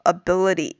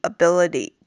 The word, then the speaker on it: ability: 这个句子里边呢，这个 eats away 注意这边要连读一下 eats away，再就是这个 at your ability at your ability 这个 at 和 your 中间可以连读一下，再就是读的时候这个 at 要弱读一些，还有要注意的就是这个 ability ability 这个词的发音，最后的这个 t 也是发成一个 fast t。